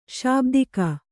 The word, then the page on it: ಶಾಬ್ದಿಕ: śabika